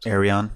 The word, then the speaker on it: Ayreon: Ayreon.